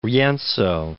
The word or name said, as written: Rienzo